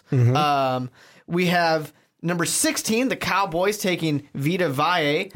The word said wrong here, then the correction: Vita Vea: Vita Vea. – Vita Vea. Damn it, I knew I was going to say it wrong.